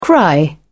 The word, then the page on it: cry: British English